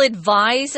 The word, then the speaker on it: advise: Well, others will advise that you bend your knees. If you advise somebody, notice the pronunciation, guys, has a Z sound.